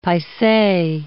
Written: paise

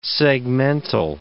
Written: segmental

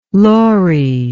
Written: Laurie

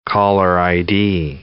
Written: caller ID

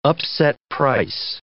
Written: upset price

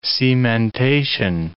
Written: cementation

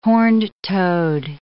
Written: horned toad

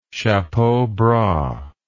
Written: chapeau bras

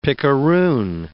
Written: pickaroon

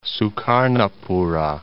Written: Sukarnapura

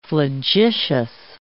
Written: flagitious

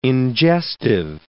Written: ingestive

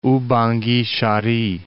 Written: Oubangui-Chari

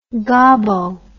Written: gobbo

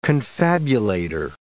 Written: confabulator

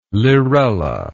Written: lirella

